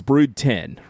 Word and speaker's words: Brood X: Like it's Brood 10, right?